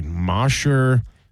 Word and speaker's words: Mosher: I think they said it's pronounced Mosher. – Mosher, yeah, you're right. They did say that. But if I said Mosher, would you know how to type it into a search engine? – How do you type it? M-O-S-E-R. – What does Mosher sound like? M-O-S-H-E-R, right? Because mm-hmm. that's how you spell it. – And so, Mosher, if you say sure, sounds like that too.